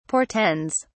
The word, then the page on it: portends: British English